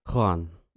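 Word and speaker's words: Xoán: a guy called Juan, which is X-O-A-N. So do you want to try and pronounce that? – Juan. – No, it's Juan. Juan. It's a bit Juan. Like, it's almost a bit like John.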